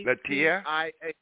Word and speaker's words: Latia: Teach me how to pronounce her name, because I'm not hearing it right. What's her name? – Huh? – It's Latia. It's L-A-T-I-A. – Latia? Yeah.